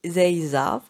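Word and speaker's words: Ezeiza: donc l'aéroport principal est Zeiza, qui s'écrit E-Z-E-I-Z-A, à Buenos Aires.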